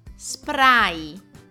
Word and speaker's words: spray: Come si pronuncia il sostantivo inglese spray? all'italiana, cioè pronunciando ogni singola lettera. Spray.